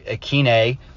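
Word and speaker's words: Westbrook-Ikhine: But I mean, you've got, if you watch the highlights from yesterday, I mean, Nick Westbrook Ekwuneme, which is apparently how you say his name now, or I mean, apparently that's how you were always supposed to say his name, but he corrected people the other day.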